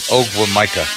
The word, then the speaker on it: Ogwumike: Ogwumike? – Yeah. – Neka? – Yeah. – Okay. – Oh, Neka. Okay. – I just, let's stick with my pronunciation. Ogwumike.